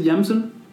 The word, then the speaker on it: JMSN: Sí, se escribe como JMSN, creo que se dice Jamson.